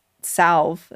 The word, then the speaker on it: salve: There's this whole idea that that witches would use this salve that they would create from like henbane. – Salve, actually. And, you don't pronounce the L like a maniac.